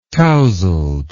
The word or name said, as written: tousled